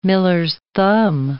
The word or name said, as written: miller's-thumb